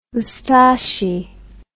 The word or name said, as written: Ustashi